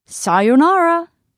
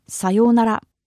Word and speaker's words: sayonara: Now, I know that in English we often pronounce it as sayonara, but in Japanese, there's really no strong intonation. It's just flat sayonara, sayonara.With no strong intonation.